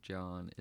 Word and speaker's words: Jawn: My name on there is Magnus John Mati Vogel, but John is spelled J-A-W-N.